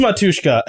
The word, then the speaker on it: Matushka: Matushki? Matushka? There is, like... – I think it's Matushka. I think it's Matushka. I said it wrong. – Yeah, I think Matushki was the plural. That's what it was.